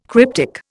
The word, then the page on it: cryptic: Indian